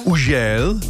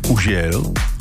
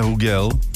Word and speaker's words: Hugel: Francouzský DJ Ujel, takhle se to přesně vyslovuje, Ujel, ne Hugel, ani Hugel, Ujel.